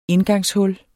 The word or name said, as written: indgangshul